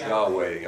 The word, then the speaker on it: Yahweh: And so we've later found out, I guess, somebody's talked to some Jewish people or something, just rabbis, and they think it's more like this, which is pronounced Yahweh.